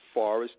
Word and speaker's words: Forrest: All right, as you guys know, you go on my Facebook page, Forrest Nance. Let me spell that for you, because some people spell my name with one R. F-O-R-R-E-S-T, Forrest Nance.